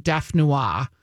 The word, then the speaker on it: dauphinoise: So I think I had baked potato, hash brown, and dauphinoise. Is that how you say it? – Dauphinoise. – Dauphinoise? – Right, okay. – Yeah, dauphinoise.